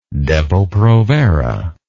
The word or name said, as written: depo provera